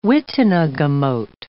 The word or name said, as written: witenagemote